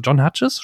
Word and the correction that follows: John Hughes: spricht man ihn so aus? – John Hughes. John Hughes, so, John genau. Hughes. John Hughes, Entschuldigung, genau.